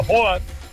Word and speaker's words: Haaland: en teoría no se debería decir Haaland, deberíamos de llamarlo Haaland, o al menos así es la correcta pronunciación,